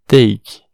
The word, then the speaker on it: take: Exemplo, take, T-A-K-E take, pegue. Uh, aqui, ó, vale ressaltar o seguinte. Perceba que nesse caso, a gente vai pronunciar o A como EI, que é o som natural do A, mas em cat a gente pronunciou o A como E, tá bom? É, ou seja, ele só tem uma unidade de som, E, e hora duas, nesse caso tem o E e o I, EI, tá bom?